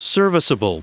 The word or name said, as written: serviceable